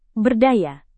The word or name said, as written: berdaya